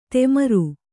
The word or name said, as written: ತೆಮರು